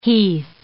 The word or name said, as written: heath